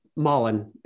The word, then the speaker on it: Malin: Did you date Malin Ackerman for like a hot second? – Malin. It's pronounced. – Malin. Sorry.